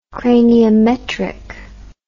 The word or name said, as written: craniometrically